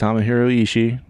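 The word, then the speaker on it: Tomohiro Ishii: No, it's just Tamahiro Ishii. You don't pronounce both I's in his name.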